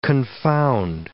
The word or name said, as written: confound